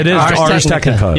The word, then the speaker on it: Ars Technica: Is it Ars Technica? – It is Ars Technica.